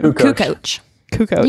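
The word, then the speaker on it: Kukoč: We have starters Michael Jordan, Scottie Pippen, Luke Longley, Tony Kukoc. How do you say that name? – Kukoc. You had it.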